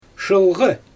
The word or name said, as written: ʃɯlɣɯ